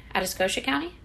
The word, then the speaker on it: Atascosa: I think I'm saying that right. Atascosa County. – Sounds right.